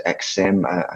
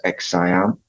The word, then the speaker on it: XSIAM: They haven't come from an endpoint origins per se, but what they've done with the XSIAM, Simon mentioned it earlier, described it as XSIM. I think it's pronounced XSIAM.